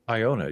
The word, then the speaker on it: Iona: Is that how you say it? – Iona, yes.